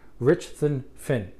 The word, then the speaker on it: Richthofen: His name was Manfred von, and I hope I say this right, Richthofen. I'll spell it. R-I-C-H-T-H-O-F-E-N.